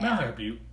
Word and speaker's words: Malheur Butte: I do not know if I'm saying that right. I don't think that I am. Melhair Butte.